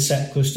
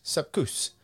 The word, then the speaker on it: Sepp Kuss: And so I'm going to go through here and just show, not how necessarily I pronounce it, but how it's supposed to be pronounced. And this is Rob Hatch. – Thankfully, with Sepp Kuss to show him his worth.